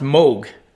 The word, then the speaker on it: Moog: It's actually, I just heard something on this, it's pronounced Moog. I called it Moog all along, but it's the Moog synthesizer.